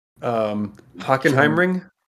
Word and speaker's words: Hockenheimring: Hockenheimring? – No, but you're close. – I also could have pronounced that wrong. Like, no, you said it actually correctly.